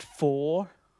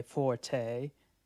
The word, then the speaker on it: forte: It's actually pronounced four, but we say forte.